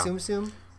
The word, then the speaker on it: Tsum Tsum: Is that how you pronounce it? Yeah. Tsum Tsum? – Yeah.